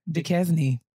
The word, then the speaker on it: Duquesne: Duquesne.